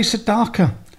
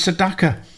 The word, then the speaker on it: Sedaka: Neil Sedaka used to say, "Why do people say Sedaka? My name's Sedaka."